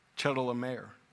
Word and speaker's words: Chedorlaomer: Chedorlaomer.